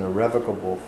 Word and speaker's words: irrevocable: Prabhupada says, is established as an irrevocable fact. Irrevocable, not irrevocable. You happen to be in educated circles. People may know the correct pronunciation of this word is irrevocable, not irrevocable, as we say many times in this gone.